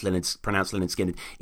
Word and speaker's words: Lynyrd Skynyrd: version is 9 minutes 4, and Tuesday's Gone on the original debut, pronounced Lynyrd Skynyrd, is 7.5 minutes.